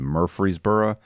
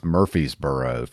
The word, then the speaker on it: Murfreesboro: For those of you who are outside of Tennessee, it's pronounced Murfreesboro. For those of you who live in there, it's Murfreesboro for some reason.